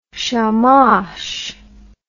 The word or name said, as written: shamash